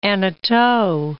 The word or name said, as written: Hanotaux